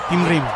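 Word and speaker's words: Tim Ream: ¿Cómo se dice el 13, el zurdo que juega en el Charlotte de Estados Unidos? – Team Rim. – Muy bien. Team Rim, Richard Robinson, Freeman.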